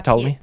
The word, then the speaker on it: Ptolemy: Yeah, Ptolemy. The P is silent. – The P is silent. – Is it? – Yes. – Yes. – It's like pterodactyl.